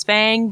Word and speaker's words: Phaing: Uh, I have decided the cat's name is Fang, but it's spelled P H A I N G because that is an elf name, um, and I feel like elves would give an elf name to a cat.